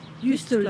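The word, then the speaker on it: Juist: Wie wird das ausgesprochen? – Jüst.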